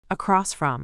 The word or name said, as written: across from